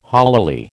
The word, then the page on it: holily: American English